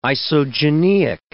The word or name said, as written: isogeneic